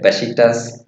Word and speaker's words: Beşiktaş: Beşiktaş.